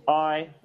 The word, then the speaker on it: Ahi: He's called I, that's spelled A-H-I.